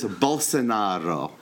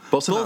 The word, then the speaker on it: Bolsonaro: Okay, but his name is Bolsonaro. – Bolsonaro. – Bolsonaro. Right. See, I'll even roll the R.